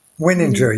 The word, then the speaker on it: Wininger: Wininger, yes.